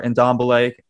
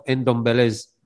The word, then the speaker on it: Ndombele: And I'm going to say, you know, I'm one of Ndombele's, or Ndombele. I don't know how Nick pronounces his name. It's real funny. But Ndombele. – It's the N.